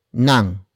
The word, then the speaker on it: ng: It is followed by nang, which is spelled as ng.